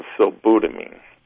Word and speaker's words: sulbutiamine: And the name of that product is sulbutamine. That's how I pronounce it, S-U-L-B-U-T-I-A-M-I-N-E.